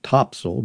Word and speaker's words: Topsail: The map shows Topsail, pronounced Topsail by those who have been here more than once, to be pencil thin.